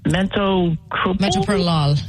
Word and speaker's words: metoprolol: The other medicine they put me on, I'm trying to remember how to pronounce it, metoprolol.